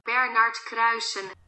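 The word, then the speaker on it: Bernard Kruysen: I did consult my trusty Google assistant, who informed me that this is the proper way to say his name. – Bernard Kreisen. – I don't always attain quite that pronunciation, but if you need a reference on how to actually say it with a proper Dutch accent, that's how you do it.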